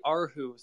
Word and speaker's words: Erhu: It's called the Arhu. So Arhu is spelled Okay.